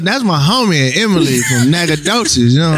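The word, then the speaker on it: Nacogdoches: To see it spelled out, Nagadoches does not come to mind.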